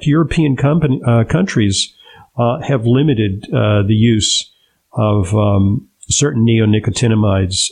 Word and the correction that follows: neonicotinoids: I'm mispronouncing it. Neonicotinoids.